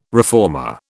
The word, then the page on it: reformer: British English